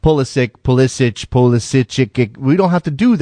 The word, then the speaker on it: Pulisic: Christian Pulisic wants it Pulisic because he thinks that's more American, but his grandfather says Pulisic because that's the more Croatian pronunciation.